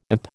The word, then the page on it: imp: American English